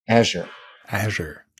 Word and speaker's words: Azure: Azure. – I know, as a German, you always pronounce it as Azure.